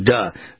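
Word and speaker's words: Da: Spike Lee doesn't know how to say the. It's got to be duh. Duh five, D-A.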